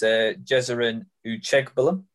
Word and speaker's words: Jezerin Uchegbulam: It is, and I've worked hard on the pronunciation to make sure it's right. It is Jezerin Uchegbulam.